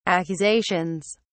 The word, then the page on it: accusations: British English